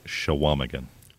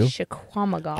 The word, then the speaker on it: Chequamegon: So most everything that I've been coming across is Chequamegon. – Whoa, I was way off. – No, you weren't too bad. You just pronounced the Q. – Chequamegon.